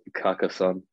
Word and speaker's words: Carcassonne: Carcassonne. Well, that's how you say it, like the English way, just like Carcassonne. Like it's pretty simple. It's like almost like the same, like in French.